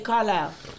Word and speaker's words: Carlisle: Like when you say it, you say Carlisle. It sounds like I L I L E. But when you spell it, it's an S in there.